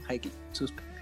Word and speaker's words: Highly Suspect: Verás, la banda se llama Hijo de puta, Highly Suspect, sí se dice así, Highly, como de alto, Highly Suspect.